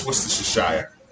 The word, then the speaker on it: Worcestershire: Nigga, it's Worcestershire. – It's Worcestershire. – It's Worcestershire. – But the first word is Worcester. – Worcestershire. – The first word is Worcester. – Worcestershire. – Worcestershire.